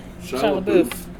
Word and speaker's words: Shia LaBeouf: Shia LaBeouf. – Shia LaBeouf.